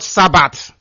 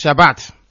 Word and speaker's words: Shabbat: It is Shabbat in Israel. Not Sabbath. It's Shabbat. That is correct pronunciation.